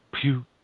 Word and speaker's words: Pugh: With Alan Grant as the writer, Steve, no, I don't want to say this wrong, Pugh, it's got to be Pugh,